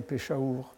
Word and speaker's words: Peshawar: surtout si vous le prononcez à, l'indienne, Peshawar.